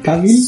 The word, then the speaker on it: Cavill: Ay, güey, es un pinche video que está, es Henry Cavill, güey, diciendo cómo se pronuncia Cavill. – Ok. Cavill. Cavill. – Eh, pero es que dice Cabil. Suena como.